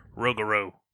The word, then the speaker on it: Rougarou: The Rougarou, which is a beast from Rougarou. Is that how you say it? – You have to say it more Cajun, like Rougarou.